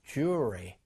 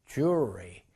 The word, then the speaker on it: jewelry: 요 L 사운드가 거의 없어져요. Jewelry, jewelry 이렇게 발음이 됩니다.